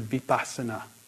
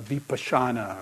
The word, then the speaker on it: vipassana: You know, it's also from the word vipassana, or vipassana, or vipassana, or, you know, all the dozens of pronunciations, but the V means to see.